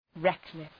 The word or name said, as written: reckless